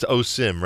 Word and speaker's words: Osum: Couple of Miami songs in the brand new album that we really haven't featured, Mugain and Osum. At least that's the, it's Osim, right? On the track, the way it's spelled, it's O-S-U-M.